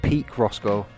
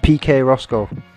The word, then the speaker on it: PK Roscoe: P-I-Q-U-E-P-K-U-E. PK. And then Roscoe. R-O-S-C-O-E. I'm saying that because I mispronounced his name. The first time I ever played him on the podcast, I think I called him Peak Roscoe. But it's PK Roscoe.